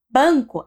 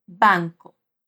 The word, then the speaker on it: banco: Em português, a gente diz banco, com a letra A bem nasal por conta da N, ban. É como se tivesse um tio ali, ban, banco. Mas em espanhol a gente não faz isso. Mesmo que a N tenha esse som nasal, quer dizer que o ar saia pelo nariz quando a gente pronuncia, a A vai ter som de A. Ou seja, a gente não faz ban, a gente diz ban, banco.